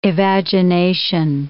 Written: evagination

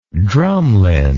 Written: drumlinoid

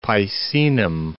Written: Picenum

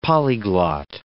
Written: polyglot

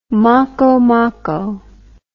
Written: makomako

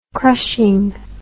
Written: crushingly

